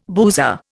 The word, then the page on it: boozer: Indian